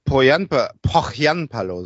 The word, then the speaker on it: Pohjanpalo: Ja, vielleicht hat Pohjanpalo, so wird er ausgesprochen, seine Kontakte spielen lassen.